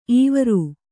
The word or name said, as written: ಇವರು